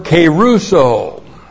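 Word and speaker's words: keruso (κηρύσσω): Three, it comes from the verb keruso.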